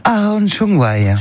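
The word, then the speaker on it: Áron Csongvai: Je prononce Aaron Chongwai. – C'est parfait, c'est comme ça qu'on dit en hongrois, effectivement.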